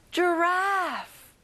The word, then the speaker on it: giraffe: 기린, giraffe. 자, 에 발음을 크게 해볼까요? Giraffe.